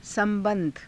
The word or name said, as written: sa^ba^dha